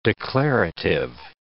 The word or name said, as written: declarative